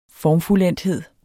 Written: formfuldendthed

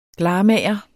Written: glarmager

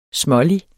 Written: smålig

